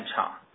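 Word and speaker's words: Zhang: Someone may find it's hard to pronounce my last name, so I would suggest reading ZH like CH. So it sounds like Jian Chang.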